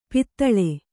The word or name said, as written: ಪಾತಾಳಿ